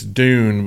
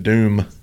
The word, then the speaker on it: dune: It's dune, but we said doom.